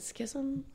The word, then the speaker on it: schism: I feel like the schism in the Republican Party, is it schism? Is yes. – I'm saying it right?